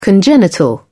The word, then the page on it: congenital: British English